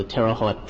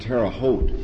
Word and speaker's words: Terre Haute: the Chief Medical Officer of the Terre Haute Prison Hospital. – Perhaps as just as an ex-Midwesterner, it bothers me, it's pronounced Terre Haute.